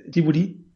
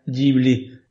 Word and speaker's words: Ghibli: Y si bien en realidad el nombre es italiano y la pronunciación debería ser Ghibli, porque de hecho la palabra se escribe como en italiano, G-H-I-B L-I, Ghibli, la pronunciación se adaptó al japonés, por lo cual, mal que pese la pronunciación, en realidad en japonés es Jiburi, y em, digamos una adaptación más o menos correcta sería Ghibli en castellano para no hacernos tampoco tanto drama en la cabeza, ¿no es cierto?